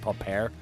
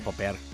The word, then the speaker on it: Popper: Yeah, I think they would pronounce it Popper. Popper, yeah.